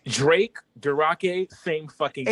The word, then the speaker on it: Drake: Yeah, Drake. – Oh, the bitch said Durake. Like, bitch. – Yeah, you gotta say the name right.